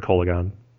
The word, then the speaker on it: Kolaghan: Another interpretation could be Kala, but I don't think in the Khans context an O would be pronounced the same as an A. I'm reading a lot in, but I've always heard Kolaghan.